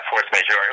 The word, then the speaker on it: Force Majeure: how do they pronounce it, Force Majeure? – Yeah, Force Majeure.